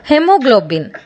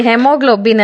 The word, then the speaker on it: haemoglobin: ಅದೇ ರೀತಿ ಈ ಹಿಮೋಗ್ಲೋಬಿನ್ ಕೂಡ, ಇದು ಆ್ಯಕ್ಚುಲಿ ನಾವು ಹೇಳಬೇಕಾದ್ದು ಹೆಮೋಗ್ಲೋಬಿನ್ ಅಂತ. ಇದರ ಸ್ಪೆಲ್ ಮಾಡ್ತೀನಿ ನೋಡಿ, HAEMOGLOBIN, ಹೆಮೋಗ್ಲೋಬಿನ್. ಎ ಇದೆ, ಶುರುವಲ್ಲೇ ಎ ಇದೆ, ಆದರೆ ಅದ್ರ ಉಚ್ಚಾರ ಆಗ್ತಾ ಇಲ್ಲ, ಕರೆಕ್ಟಾ?